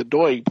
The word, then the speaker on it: Doig: Lexa Doig. – Doig? – Is Doig. That how you pronounce her name? Sure.